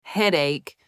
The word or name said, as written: headache